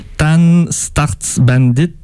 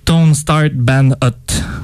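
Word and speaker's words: Tonstartssbandht: Oui, puis après, on va finir le bloc avec Pass Away de Tone Start Bandit. Je sais pas comment je veux pas vous l'appeler, ce serait trop long, mais Wikipédia dit qu'il faut prononcer Tan Starts Bandit ou Tone Start Band Hot.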